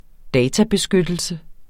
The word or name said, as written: databeskyttelse